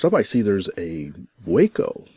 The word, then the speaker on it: Waco: Waco.